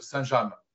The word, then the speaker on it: Saint-James: Et on prononce bien Saint-James.